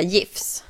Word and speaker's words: GIF: Egentligen ska man ju säga GIFs. – GIFs? Ja. – Egentligen uttalas ju det med ett J i början.